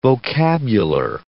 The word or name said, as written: vocabular